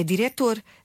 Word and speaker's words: diretor: Não, então, mas eu não digo diretor. Pois não, é diretor. Não escrevo C.